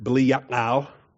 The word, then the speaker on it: Belial: So if you want to pronounce it Belial, have fun. Pronounce it Belial. Put the accent on the ya.